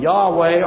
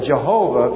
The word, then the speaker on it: Yahweh: That is a stumbling block to the wife of Yahweh or the wife of Jehovah, if you wish to say that. That is a way of pronouncing YHVH, which is unpronounceable. But we pronounce it.